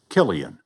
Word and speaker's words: Cillian: "2023 was the year the world learned to pronounce Killian. The ancient Irish name seemed to be on everyone's lips as the film Oppenheimer became a blockbuster with 13 Oscar nominations, including Best Actor for Cillian Murphy.